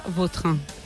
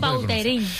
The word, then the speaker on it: Vautrin: La original francesa, Vautrin. – Ahí pega un Bauterín.